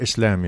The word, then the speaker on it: Islam: Islam, yes.